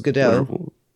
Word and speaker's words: Gadot: I thought it was Godot. – Well, I think on Jimmy Fallon, she says that she's not French, so you pronounce the T.